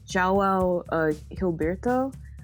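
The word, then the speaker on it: João Gilberto: Um, I always like get his name wrong, but it's pronounced like um, João uh, Gilberto.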